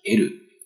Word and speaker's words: える: 如果我们翻一翻字典，可以看到这个得到的得啊，加一个 lu，这个 uru 呢，实际上在字典里面是有两个读音，一个是读 uru，另外一个是读 elu。